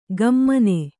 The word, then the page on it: ಘಮ್ಮನೆ: Kannada